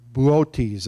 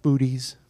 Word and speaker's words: Boötes: The largest is the Boötes, it's pronounced. Not booties.